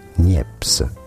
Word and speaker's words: Niépce: Joseph Nicéphore Niépce. Joseph Nicéphore Niépce. – Okay. – It'd be fine in English to say it as Joseph Nicéphore Niépce. Joseph Nicéphore Niépce. – Okay, so Joseph Nicéphore Niépce, the French inventor who created the first true photographs, was born on this day in 1765.